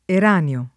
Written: Eranio

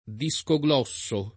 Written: discoglosso

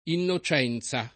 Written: innocenza

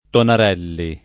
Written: Tonarelli

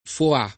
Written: Foà

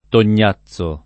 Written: Tognazzo

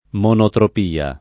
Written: monotropia

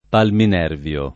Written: palminervio

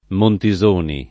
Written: Montisoni

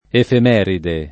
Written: efemeride

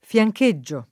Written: fiancheggiare

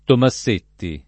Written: Tomassetti

